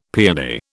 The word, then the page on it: peony: British English